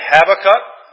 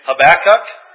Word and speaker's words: Habakkuk: I was questioned about my pronunciation of Habakkuk, so I looked it up, and there's actually three pronunciations that are all listed. You can say Habakkuk, you can say Habakkuk, and Habakkuk.